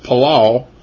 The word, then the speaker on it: palal: It's pronounced palal. P-A-L-A-L is how it's spelled in the transliterated English word version.